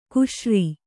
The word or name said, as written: ಕಶೇರು